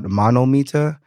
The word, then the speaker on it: Mann-O-Meter: I can recommend a place called Monometer. That place is really good. It's spelled MANN-O-meter.